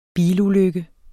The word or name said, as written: bilulykke